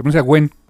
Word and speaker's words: Nguyen: Ya sé cómo se pronuncia, Nguyen. Es como decir, como Gwen Stacy, así, Dustin Nguyen se llama, es el apellido.